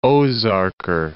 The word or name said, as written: Ozarker